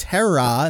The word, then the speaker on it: Hera: So it's Hera, and that's an elongated sound at the end there.